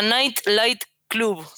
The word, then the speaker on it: Nite Lite Club: Eh, ah, recomiendo que sigan a Night Light Club, que se escribe Nite Lite Club, que es un festival de animación que hace mi queridísima amiga Macarena Roca, alias Roma.